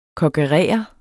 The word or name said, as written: kokkerere